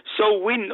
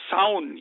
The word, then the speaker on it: Samhain: Schreiben, tut's man, schreiben tut man es Samhain mit AI, aber aussprechen tut's, äh, tut man es äh, So Win oder Sound, je nachdem.